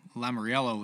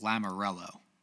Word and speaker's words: Lamoriello: I didn't think that would be a move that Lou Lamoriello would make. I don't know how to say it. It's just Lamoriello. Lamoriello.